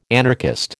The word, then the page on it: anarchist: American English